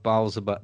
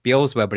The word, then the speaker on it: Beelzebub: But just to say, like, that's the etymology of Beelzebub. We pronounce it Beelzebub, but it's Beelzebub.